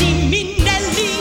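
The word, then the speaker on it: Minnelli: Prononcez ainsi Minelli. Liza Minelli. It's Italian.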